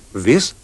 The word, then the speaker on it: this: Sentirete che nella prima parola il suono della vocale è molto più breve. – This. These. – Inoltre la prima S si pronuncia come nella parola rosso, mentre la seconda ha il suono simile alla S di rosa.